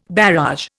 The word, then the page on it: barrage: Indian